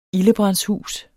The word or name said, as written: ildebrandshus